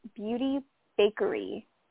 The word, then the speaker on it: Bakerie: It's called Beauty Bakery, but it's spelled B A K E R I E.